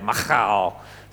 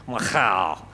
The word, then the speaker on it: Michal: Firstly, we see that David loved Saul's daughter, Michal. You've got to say it with a ch. Apparently, that's the way, Michal. So that's how her name is pronounced.